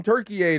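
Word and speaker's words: Türkiye: Well, Turkish people want you to call them Turkey-ay now. – Sure. I guess if I'm talking to a Turkish person, I probably would pronounce it Turkey-ay.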